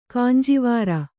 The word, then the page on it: ಕಂಜವೈರಿ: kanjavairi